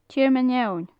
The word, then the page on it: tearmann eoin: gaelic